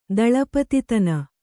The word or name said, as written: ದಳಪತಿತನ